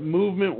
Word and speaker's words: MVMT: Also, don't forget our friends over at Movement Watches. It's pronounced movement, but it's spelled MVMT.